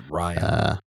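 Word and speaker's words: Riaan: It might just be Ryan, but it's R-I-A-A-N. – It's Ryan.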